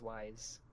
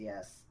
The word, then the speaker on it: Ys: Well, the other one's wise. – Oh, I thought it was yes. – I thought it was is. – Is. – Yeah, is. – That's how it is how it is. I can go all night. – Is. Apparently, that's how it's pronounced. Is.